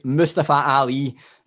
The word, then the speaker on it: Mustafa Ali: Mustafa Ali.